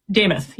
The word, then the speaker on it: DeMuth: DeMuth.